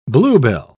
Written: bluebell

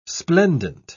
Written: splendent

